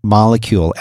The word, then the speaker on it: Molekule: You can go, it's spelled a little different. Instead of a C, there's a K. So it's spelled M-O-L-E-K-U-L-E, molecule, M-O-L-E-K-U-L-E.